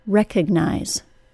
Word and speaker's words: recognize: I'm saying the word spelled R-E-C-O-G-N-I-Z-E. The first syllable of recognize is stressed. It sounds like rec. The syllable after that is reduced to schwa, and it sounds like ug.